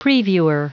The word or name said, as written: previewer